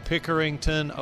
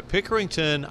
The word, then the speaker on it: Pickerington: I hope I say this right. He's the pride of Pickerington, Ohio. Is that correct, Pat? – Yeah, that's correct. That's how you say it.